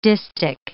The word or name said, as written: distich